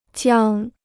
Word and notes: 江: jiāng

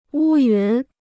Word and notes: 乌云: wū yún

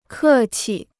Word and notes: 客气: kè qi

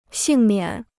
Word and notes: 幸免: xìng miǎn

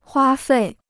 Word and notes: 花费: huā fèi

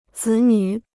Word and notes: 子女: zǐ nǚ

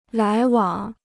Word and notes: 来往: lái wǎng

